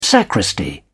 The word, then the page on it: sacristy: British English